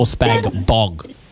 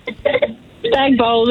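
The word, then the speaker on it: spag bol: Is it spag bowl or spag bog? – Spag bowl, the G is silent.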